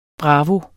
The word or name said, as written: bravo